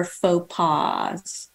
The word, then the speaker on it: faux pas: One of the ways she's done that is by admitting her faux pas.